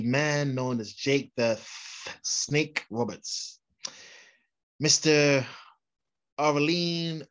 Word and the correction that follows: Aurelian: Aureline, Aureline, Mr. Clean, what is he? I believe it's pronounced Aurelian or Mr. Aurelian Smith Jr., born May the 30th, 1955, making him 56.